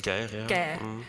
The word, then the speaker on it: Geir: Geir.